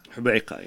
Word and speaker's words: Hobeika: There was a particularly bloodthirsty member of the Lebanese phalangists, the Lebanese fascists, named, I hope I'm pronouncing this correctly, Eli Hobeika. – Hobeika, yes.